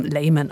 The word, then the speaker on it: Lehmann: Eigentlich müsste ich ihren Nachnamen auch deutsch aussprechen, denn ihr Vater heißt Lehmann, kommt aus Deutschland, aber sie wird 1862 in London geboren und dort spricht man ihren Namen Lehmann aus.